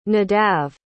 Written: nadav

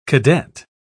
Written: cadet

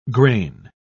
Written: grain

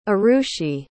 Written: Arushi

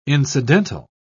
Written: incidental

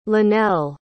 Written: lynelle

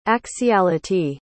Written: axiality

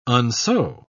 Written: unsew

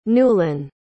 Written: Newlin

